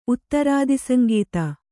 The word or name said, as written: ಉತ್ತರಾದಿಸಂಗೀತ